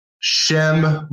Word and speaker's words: Shem: Shem rhymes with shame.